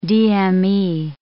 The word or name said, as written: DME